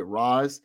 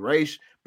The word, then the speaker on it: Ra's: Also note for all the audience who may have questions about this, it is the original creators of Ra's al Ghul pronounced it Ra's. In basically, a lot of people who are in like the Arabic language say it should be Raish. But again, it's whatever you want to pronounce it. I'm not going to sit here and tell you that one pronunciation is better than the other, but whatever you want to call him, that's what he is. Or it's Ra's or Raish, whatever you decide you want to do.